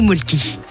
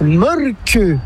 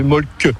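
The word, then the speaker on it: Mölkky: Inventé en Finlande en 1996, ce jeu de quilles s'écrit M-O-T-R-E-M-A-L-K-K-Y. – Ça s'écrit molki, mais ça se prononce molke. – Le molki. – Plutôt un E qu'un E, mais pas un U. Donc ça fait molque.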